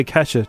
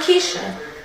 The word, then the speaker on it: Kesha: My name is Kesha. Kesha. – Yeah. Kesha. – Not Keisha. – No. – Not ketchup. – Like ketchup. – Kesha. – Kesha. – Kesha, yeah.